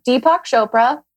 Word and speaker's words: Deepak Chopra: It's from Deepak Chopra.